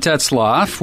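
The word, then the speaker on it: Tetzlaff: This is John Tetzloff with Tetzloff Yacht Sales. I'm going to spell that last name. It's T-E-T-Z-L-A-F-F.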